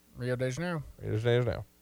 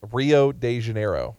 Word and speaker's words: Rio de Janeiro: Rio de Janeiro. – I didn't say it right. Rio de Janeiro.